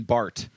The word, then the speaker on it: Bart: By Dr. Benjamin Bart. Rhymes with fart.